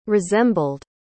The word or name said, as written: resembled